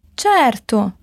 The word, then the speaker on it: certo: Esse CE se lê como TIE, tchê. Certo.